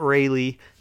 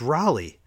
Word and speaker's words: Raleigh: So it's Raleigh, and it's Raleigh, R-A-L-E-I-G-H, which is pronounced Raleigh, not Rayleigh, not Riley, like everybody says.